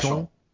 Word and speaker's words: Vachon: How did they pronounce it? It was French. – Vachon? – Was it Vachon? Vachon.